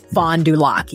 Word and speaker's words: Fond du Lac: And just as a point of clarification, Wayne said he thinks it's pronounced Fond du Lac, the name of the tribe. – I was struggling with that. Fond du Lac band. – Fond du Lac, yeah.